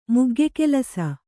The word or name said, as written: ಮುಗ್ಗೆಕೆಲಸ